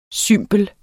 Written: cymbel

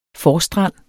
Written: forstrand

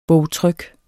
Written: bogtryk

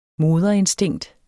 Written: moderinstinkt

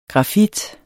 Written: grafit

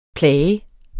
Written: plag

